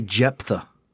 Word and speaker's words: Jephthah: It's definitely Jephthah. – No, it's a silent H. – It's a PH. It's Jephthah. – It's Hebrew. – So it's got two silent H's.